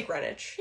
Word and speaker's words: Greenwich: I was just seeing green, because it's spelled Greenwich. – Yeah. – But you say Greenwich.